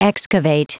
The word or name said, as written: excavate